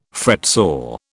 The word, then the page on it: fretsaw: British English